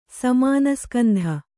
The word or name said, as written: ಸಮಾನಸ್ಕಂಧ